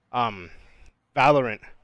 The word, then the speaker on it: Valorant: Valorant. That's how you say it.